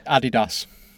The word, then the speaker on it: Adidas: I don't like the way you say Nike either, but how do you say the other one that begins with A, the German brand? – Adidas. – Right, that's fair enough.